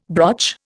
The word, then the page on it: broch: Indian